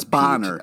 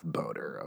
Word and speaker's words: Bohner: Just to be clear, B-O-H-N-E-R. – It's Bonner. It's Bonner is how you pronounce it's Bonner. – It. – It's Ralph Bonner. It's not Ralph Boner, okay? – Yeah.